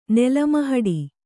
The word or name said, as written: ನೆಲಮಹಡಿ